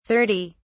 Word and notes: 30: British English

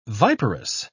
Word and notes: viperous: British English

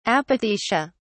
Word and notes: apothecia: British English

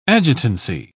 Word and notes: adjutancy: British English

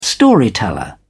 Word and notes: storyteller: British English

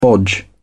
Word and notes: bodge: British English

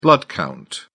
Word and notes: blood-count: British English

